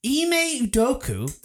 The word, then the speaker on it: Ime Udoka: Ime Udoka, I think I pronounced that correctly. – Sure, yeah, you did great the head coach of Boston,